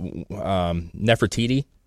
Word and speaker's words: Nefertiti: And honestly, on the plates that they have, they'll say, like, this, there was one that was a statue of Nefertiti. Is that how you say it? – Oh, yeah.